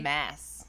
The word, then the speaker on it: Mads: I think it's pronounced Mess. Like Mess Mikkelsen. – Oh, is it? – That's what I was told by some voracious Hannibal fans at Comic-Con. – Oh, so if it's spelled M-A-D-S. – M-A-D-S. – It's Mess? – It doesn't really matter, but let's see. Denmark rhymes with jazz.